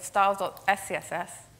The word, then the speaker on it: styles.scss: And this will only run if styles.css is newer than styles.css. Sorry, did I say that right? If styles.scss is newer than styles.css.